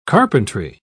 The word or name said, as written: carpentry